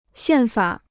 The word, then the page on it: 宪法: xiàn fǎ